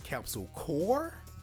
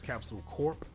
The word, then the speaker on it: Capsule Corp: and they pronounce it as Capsule Core, and that's just something that kind of bugged me, because I was fairly certain this entire time that it was Capsule Corp. I mean, Corp is a word that is an abbreviation of the word corporation, so I've always felt that it should be pronounced Corp. Now, Core is also a word, C-O-R-P-S.